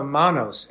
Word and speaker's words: Manos: Well, I have something here called the Manos, M-A-N-O-S. Is that how you pronounce it? – Correct.